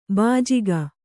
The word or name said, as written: ಭೋಜಕ